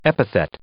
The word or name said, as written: epithet